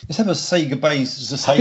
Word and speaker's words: Sega: The argument is, of course, is that going back to the retro days when they went Sega at the literal start of every single game, that's how it's said.